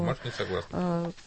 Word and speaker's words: Марш несогласных: Любые организации, как бы они ни назывались, а в данном случае движение «Марш несогласных», я правильно произношу? – Да, «Марш несогласных».